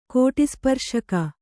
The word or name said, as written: ಕೋಟಿಸ್ಪರ್ಶಕ